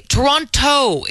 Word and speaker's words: Toronto: Now, if you're from Toronto, like I am, you know that you don't say that second T.